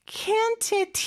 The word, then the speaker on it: can't: can't의 T 발음은요, 세게 안 하는 경우가 많아요. 그래서 잘안 들릴 때가 있는데, can으로 좀 강하게 들린다 그러면 can't일 가능성이 큽니다.